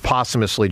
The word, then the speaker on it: posthumously: Boy, I always struggle with the word posthumously. Help me, how do you say it? – Posthumously. – Posthumously.